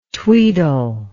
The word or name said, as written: tweedle